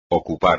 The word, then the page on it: ocupar: espagnol